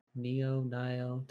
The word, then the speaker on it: Nioh: Nioh 2? Is that how you pronounce that? Nioh? – Nioh.